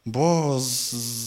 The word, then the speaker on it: Boaz: Y termina en Z. Boaz. Así se pronuncia en inglés.